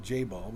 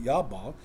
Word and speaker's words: Jabal: That's how you pronounce it. Not, I always said Jabal, but Yabal.